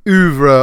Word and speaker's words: oeuvre: It's spelled O-E-U-V-R-E, and as you could guess, is another French word. Like most French words, its spelling doesn't match its pronunciation to our Anglo-Saxon eyes and ears, so that's one way the word is cool, oeuvre.